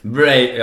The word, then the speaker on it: Wraith: Säger man Wraith? – Wraith, jag vet.